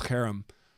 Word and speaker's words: cherem: In Hebrew, the term is cherem, or it sounds like the word harem, like a group of wives.